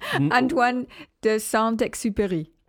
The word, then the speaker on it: Antoine de Saint-Exupéry: How do you say that name? Antoine de Saint-Exupéry. Either way, just saying like... – Antoine de Saint-Exupéry. – Good. Very good.